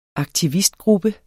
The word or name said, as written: aktivistgruppe